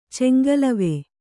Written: ಚಂಗಲವೆ

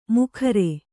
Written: ಮುಖರೆ

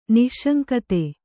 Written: ನಿಸ್ಸಂಗತೆ

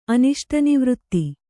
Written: ಅನಿಷ್ಟನಿವೃತ್ತಿ

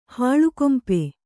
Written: ಹೊಲೆಗಂಪು

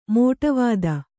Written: ಮೋಟವಾದ

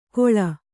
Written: ಕೊಳ

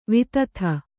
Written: ವಿದಿತೆ

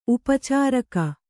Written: ಉಪಚಾರಕ

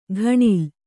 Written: ಘಣಲು

